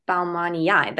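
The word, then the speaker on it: baumannii: I'm sorry, when I heard you say Balmanii, that is probably how you say it, hey? – I've always said it that way because it's got two eyes.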